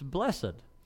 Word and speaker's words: blessed: Now, we're looking at this word, and this word, and I'm spelling it on purpose a lot of the times, B-L-E-S-S-E-D, for this point. Now, how do we say it? And you might say, well, it's blessed. Or you might say it's blessed. And the answer is you're right. Depending on what dictionary you look at and what time, as we look at the English language, the way we say things changes.